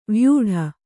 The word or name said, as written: ವ್ಯೂಢ